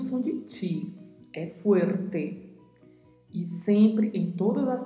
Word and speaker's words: fuerte: Na pronúncia se atente ao som da letra U também, na primeira sílaba. Fuerte. E essa letra R está localizada no meio da palavra e às vezes é difícil de fazer a pronúncia, então treine bastante.